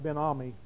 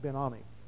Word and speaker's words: Benoni: I've heard it pronounced Benami, but it's not. It's Benoni.